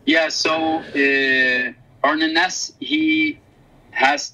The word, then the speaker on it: Arne Næss: And I'm particularly inspired by a Norwegian philosopher called Arne Næss, who is considered one of the founding fathers of eco-philosophy.